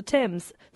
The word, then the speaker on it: Thames: The saviour, in part, was due to a few square miles across the Thames, south of... – You said Thames, right? – I said it right.